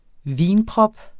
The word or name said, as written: vinprop